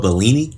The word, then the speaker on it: Bellini: and now we're moving on to um, the uh, opera Norma by Vincenzo Bellini. Is that how you say his name? – Yeah, yeah.